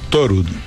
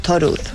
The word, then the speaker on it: Thorud: Thorud. – Thorud. – Thorud. Como el dios Thor, porque es un apellido noruego.